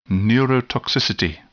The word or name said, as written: neurotoxicity